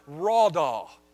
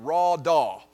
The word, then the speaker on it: radah: That word is the Hebrew word radah. You pronounce it like R-A-W-D-A-W, radah.